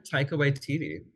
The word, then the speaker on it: Taika Waititi: Yeah, Taika Waititi.